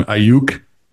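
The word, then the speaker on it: Aiyuk: He's one of him, McCaffrey, and I'm going to say his name wrong, Ayuk.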